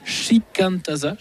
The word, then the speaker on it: Shikantaza: Alors le dernier album, votre dernier album est sorti un petit peu plus d'une année maintenant, il est baptisé Shikantaza, j'espère que exact.